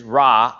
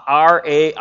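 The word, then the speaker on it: ra (רע): The Hebrew word here is ra, R-A apostrophe, so it's like ra, kind of that kind of pronunciation, ra, and R-A or R-A-C-H, ra.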